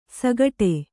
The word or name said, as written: ಸಾಗಾಡೆ